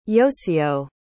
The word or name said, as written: yosyo